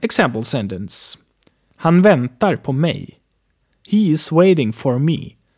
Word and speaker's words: mig: Mig is written M-I-G, but is pronounced like M-E-J.